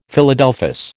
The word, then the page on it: Philadelphus: American English